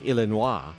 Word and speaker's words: Illinois: Which, you know, they put an S on the end of it. – It's probably because it's supposed to be pronounced Illinois.